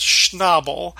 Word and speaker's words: Schnabel: And even if it were, it would be pronounced Schnabel, which leads Carla to making a joke about the pronunciation of table as Tobble, which makes Lud crack up.